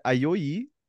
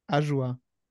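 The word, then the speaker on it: Ajoie: Alex hat sich extra schweizerdeutsche Kommentatoren angehört, damit er sagen kann, wie hier äh, Ayoyi äh, ausgesprochen wird. Und zwar wie? Ajoa.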